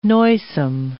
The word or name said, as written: noisome